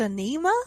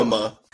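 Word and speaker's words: Ænima: Are you sure it's pronounced Anima? – I'm pretty sure. – Oh, it's spelled weird. Okay. Okay, yeah. I thought you were trying to say... – It's not enema.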